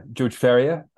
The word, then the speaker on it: Ferrier: Yeah, George Ferrier, like a horse farrier, you know.